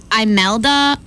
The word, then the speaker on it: Imelda: Then we have Imelda. I-M-E-L-D-A.